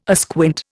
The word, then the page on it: asquint: Indian